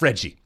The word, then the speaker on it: Fred: they had it two syllables pronounced Fredgy.